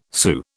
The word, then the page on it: Sioux: British English